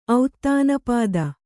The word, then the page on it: ಔತ್ತಾನಪಾದ: auttanapāda